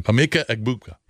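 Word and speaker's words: Emeka Egbuka: Amika Egbuka.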